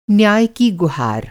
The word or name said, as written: गुहार